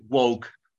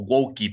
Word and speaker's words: woke: woke, como le llaman, ¿no? Eh, que es toda esta, o wokey también a veces oigo que lo pronuncian algunos,